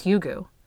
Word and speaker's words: Hygge: I had to look at how to pronounce it because it's spelled H-Y-G-G-E, but it's pronounced Hygge.